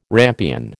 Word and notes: rampion: American English

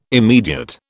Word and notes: immediate: British English

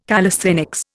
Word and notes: calisthenics: Indian

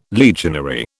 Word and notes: legionary: British English

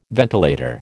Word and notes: ventilator: American English

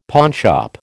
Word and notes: pawnshop: American English

